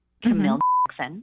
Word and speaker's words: Camille Jackson: My name, Camille Jackson. – Mm-hmm. Okay. – And it sounds just like you would spell it.